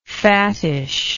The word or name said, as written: fattishness